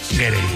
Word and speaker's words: Gere: Estaba pensando yo que si te apellidas Ger, bueno, ¿cómo se dice Ger o Gerre? – Gerre.